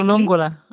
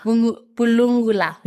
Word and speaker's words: Bulungula: Bulungula. – Bulungula.